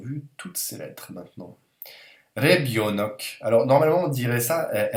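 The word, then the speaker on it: Rebionok (ребёнок): Et le russe accentue toujours le yo, en fait. Ce qui fait qu'on va atténuer encore un petit peu cette lettre-là. Et ça se prononce en fait Rebionok. C'est presque un i.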